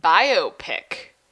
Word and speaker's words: biopic: Apparently, TriStar Pictures is in talks for a Vince McMahon biopic. – The film biopic? – Yes. The film studio. – It's the same thing, but it's called pronounced biopic. – Biopic.